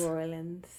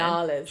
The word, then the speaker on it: New Orleans: It's Nollins. She said it's Nollins.